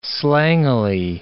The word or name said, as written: slangily